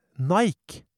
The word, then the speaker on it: Nike: På engelsk uttales Nike Nike, og da kan de jo gjette hvor den verdensberømte produsenten av sportsutstyr har navnet sitt fra.